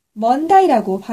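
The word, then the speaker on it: Monday: 특히 A 발음을 R로 부르지요. 예를 들어 월요일을 뜻하는 Monday를 먼다이라고 발음해요.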